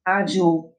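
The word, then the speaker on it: audio: Adio, eu ouço. A pronúncia dos romanos provavelmente lembrava o nome dos carros Audi ad universite harmor sumi.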